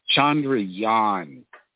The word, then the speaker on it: Chandrayaan: It's Chandrayaan. It's pronounced that way with the emphasis on the last syllable.